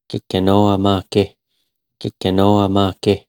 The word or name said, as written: Gakinoamaage